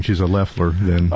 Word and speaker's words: Loeffler: So, by the way, it's Kelly Loeffler. You keep calling her Loeffler, but it's Loeffler. – It's Loeffler? – Yes, it is. – What's spelled Loeffler? – The O is silent.